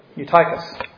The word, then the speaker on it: Eutychus: Eutychus. Did I say that right? Yeah, that's what. Eutychus, who was sinking into a deep sleep.